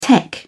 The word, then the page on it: tech: British English